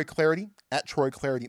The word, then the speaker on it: Clardy: Follow me up on Twitter, at Troy Clarity, the last name is spelled C-L-A-R-D-Y.